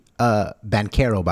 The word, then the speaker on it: Banchero: But, yeah, because I wanted to see Banchero, by the way, it's how you pronounce it.